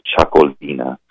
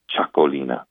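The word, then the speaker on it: Txakolina: this Chacolina. It is spelled, by the way, Txacolina, Chacolina.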